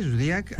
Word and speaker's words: Zodiac: Zodiac va ser un assassí, que es pronuncia així, Zodiac, en sèrie, que va actuar a nord de Califòrnia des del mes de desembre de 68 fins a l'octubre de 69.